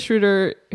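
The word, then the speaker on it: Schröder: Rhymes with shooter. – Yes. They're both on fire, especially Dennis. Like, especially both of them. Paul George makes almost every single three he takes. And Dennis Schroeder, who looks like a little tiny baby.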